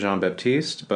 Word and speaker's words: Jean-Baptiste: I will introduce myself as Jean-Baptiste, but I'm totally comfortable with people calling me Baptiste.